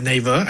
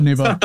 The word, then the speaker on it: Naver: Well, first of all, I pronounce it as Naver. I hope that's correct. – Naver. So, okay, Naver.